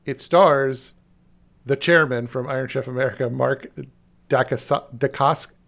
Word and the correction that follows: Dacascos: Well, Daca. wow, I can't say his name. Holy keep, shit! Keep trying, Mark. Mark. – Dave, correct him. – Dacascos. Mark Dacascos.